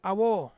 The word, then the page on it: awo: Ansus